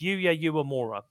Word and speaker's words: Yuya Uemura: I keep saying that wrong. It's Yuya Uemura versus the incredibly versatile and partner to, of course, Courtney Rush, Andrew Everett.